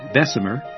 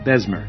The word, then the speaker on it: Bessemer: Some pronounce it Bessemer, and others reduce it to two syllables, Besmer.